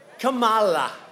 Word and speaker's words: Kamala: If you pronounce her name wrong, she goes crazy. Kamala, like a comma.